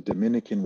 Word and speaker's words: Dominica: That's how you pronounce it, Dominica? – Yeah, so there's the confusion, man. Is always there's the Dominican Republic. – Right. – And then there's Dominica. – Yeah. – And they're two separate countries.